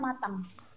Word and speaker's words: matin: matin. Itu artinya pagi. – Aha. – Tapi kalau di Perancisnya bacanya matang.